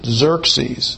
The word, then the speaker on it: Xerxes: So Artaxerxes is the son of Xerxes, and the X in English really represents a Z sound. So instead of it being Xerxes or something like that, we just pronounce it Xerxes.